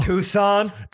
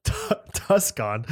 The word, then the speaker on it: Tucson: Tucson. – Tempe. – As Isaac pronounces it. – Tuscon.